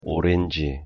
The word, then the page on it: 오렌지: Korean